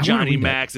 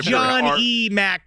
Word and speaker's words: John E. Mack: Well, when you say it like Johnny Max, it sounds like he's in Mortal Kombat. – John E. Mack, but I feel like he did it.